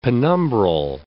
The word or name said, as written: penumbral